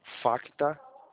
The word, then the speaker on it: Facta: Facta. O C é mudo, tá?